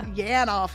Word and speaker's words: Yanoff: I'm like, Yanoff? Yes. Yanoff? Yeah. – Yanoff, yes. Y-A-N-O-F.